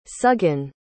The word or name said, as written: suggan